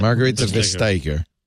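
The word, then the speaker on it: Margrethe Vestager: The telecoms in the EU have managed to convince Marguerite how do we say that? Margarita Vestager. – Yes,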